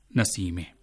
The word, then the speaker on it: نسیمه: سامعین آپ نے یہاں جو جملے سنے ان میں خواتین کے جو نام ہیں رضیہ، ناصرہ اور نسیمہ، ان کا فارسی تلفظ آپ نے ملاحظہ کیا کہ رضیہ کو رضیہ کہا گیا، ناصرہ کو ناصرے کہا گیا اور نسیمہ کو نسیمے.